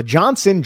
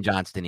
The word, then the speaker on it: Johnston: Yeah, no, the Johnson Johnston. – I don't like the Johnson Johnston either. I do not like it. At all. – It's a very important T. You need to pronounce it.